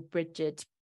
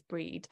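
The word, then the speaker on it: Brigid: So, or Bridget, or Bridget. I pronounce it as Breed, but she is this virgin Celtic goddess, and she is the bearer of light.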